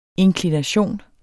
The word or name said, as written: inklination